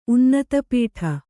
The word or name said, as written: ಉನ್ನತಪೀಠ